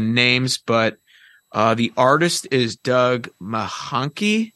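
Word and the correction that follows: Mahnke: Oh, Mankey? – Mankey, thank you.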